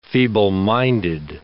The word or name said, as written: feebleminded